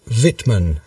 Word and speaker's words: Wittmann: and this is Mikhail Wittmann. Have I pronounced that correctly? – That's right.